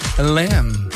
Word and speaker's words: Lemme: Mais je vais rajouter Liam Rance tantôt, Mike Domero avec Beta Lem. L-E-M-M-E. Ça s'écrit de même. Ça se prononce pas mal comme ça.